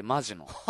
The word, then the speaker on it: 本気: 本気と書いてマジのが名前。